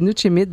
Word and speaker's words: Nuchimit: On va présenter aujourd'hui un livre qui s'appelle, et là je vais bien le prononcer, Nuchimit, c'est bien ça? – Je crois, là je ne suis pas une spécialiste de l'Innu Eman, mais oui, Nuchimit, on dit Nuchimit, bain de forêt.